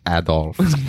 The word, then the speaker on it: Adolf: Adolf, you got to say it correctly. – Adolf.